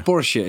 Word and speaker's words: Porsche: Zeg ik het goed? Porsche. – Porsche is het, ja.